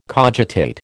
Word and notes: cogitate: American English

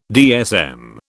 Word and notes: DSM: British English

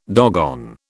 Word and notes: doggone: British English

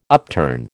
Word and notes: upturn: American English

